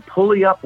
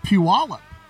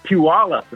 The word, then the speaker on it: Puyallup: And I think he was from Pulley Up, Washington. Does that sound right? Is there a Pulley Up? – Yeah, it's pronounced Puyallup. – Puyallup.